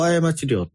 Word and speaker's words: 過料: あんまり、ね、例えば、うん、法律一番最初に出てくるのが、過料過ち料って読む、もう一つの過料とが料、これは聞いたことはあります。